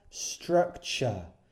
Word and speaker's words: structure: Estructura, structure. Si una palabra termina en T-U-R-E, it's not tur, it's ch.